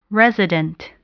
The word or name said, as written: resident